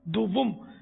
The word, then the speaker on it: دوم: اول خلیفہ سیدنا حضرت ابو بکر صدیق رضی اللہ تعالی عنہ, دوم خلیفہ جس کو عام عوام دوم پڑھتی, دوم دوم نہیں اصل تلفظ ہے, دوم دال اور واو پر پیش ہے, نہ بھی لگائیں پیش کوئی ضرورت نہیں.